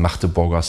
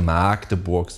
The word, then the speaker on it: Magdeburg: dass ich Hochdeutsch spreche und nicht diesen Magdeburger Slang habe. Trotzdem nervt es mich, wenn Leute Magdeburg sagen und nicht Magdeburg. Also das G wird als K ausgesprochen und nicht als langes A,